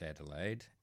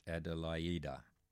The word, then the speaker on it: Adelaide: Beethoven's Aida, Adelaida, not pronounced Adelaide, but pronounced Adelaida, Opus 46, with Friedrich Schoecker at the piano, and the tenor was, if you didn't guess, Jussi Björling.